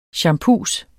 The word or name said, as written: champus